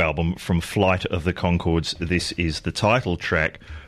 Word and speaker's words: Conchords: And before that, Flight of the Conchords. Don't forget the silent H in it. Chords as in music.